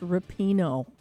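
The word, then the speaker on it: Rapinoe: So my producer, Mr. Mike, says it's pronounced Rapino, her last name.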